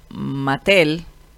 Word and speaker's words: Mattel: Oiga, estaba leyendo que la marca de juguetes Mattel, se dice así, ¿verdad? Sí.